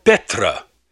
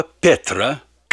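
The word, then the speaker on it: «петра» (πέτρα): Слово же «камень» произносится «петра» и означает «большой камень», «скала». И вот это слово «петра», «камень», Господь употребил, сказав, что на нем Он построит свою церковь.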